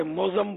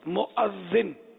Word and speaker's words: مؤذن: دیکھو یہ بچہ ہو کے مؤذن کہہ رہا ہے، حالانکہ بڑے بڑے اس کو کیا کیا بولتے ہیں، موزم بولتے ہیں، موزم پتا نہیں جو سمجھ میں آتا ہے بول رہے ہوتے ہیں. تو اصل لفظ ہے تلفظ مؤذن، مؤذن.